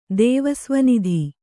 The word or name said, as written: ದೇವಸ್ವನಿಧಿ